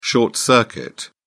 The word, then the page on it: short circuit: British English